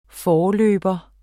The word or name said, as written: forløber